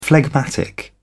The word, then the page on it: phlegmatic: British English